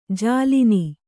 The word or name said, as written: ಜಾಲಿನಿ